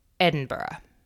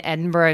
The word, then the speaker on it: Edinburgh: It looks like, and this is for all my American friends, it looks like Edinburgh. It is most assuredly motherfucking not. So don't pronounce it that way.